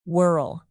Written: whorl

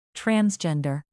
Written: transgender

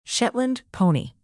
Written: shetland pony

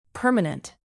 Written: permanent

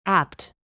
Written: apt

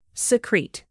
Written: secrete

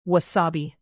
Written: wasabi

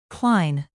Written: cline

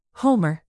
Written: homer

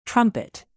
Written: trumpet

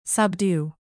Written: subdue

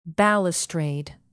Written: balustrade

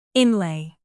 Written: inlay

